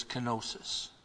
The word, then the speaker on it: kenosis: And it's shown in several things, and the first thing it's shown in is his kenosis. That's a Greek word. K-E-N-O-S-I-S. We spell it. But the Greek word is kenosis.